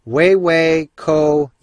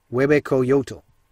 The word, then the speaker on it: Huehuecoyotl: Now this one, it's kind of a crazy looking name, but you pronounce it Huehuecoyotl. Huehuecoyotl.